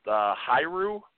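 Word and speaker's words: Hiura: But I believe the last name is pronounced Hyru.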